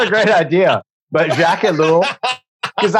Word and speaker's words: Jacques Ellul: Jacques Ellul, is that how you say it? That's how I say it, okay. Jacques Ellul. Jacques Ellul, and why he is such an important person to listen to, simply because you quote him quite a bit.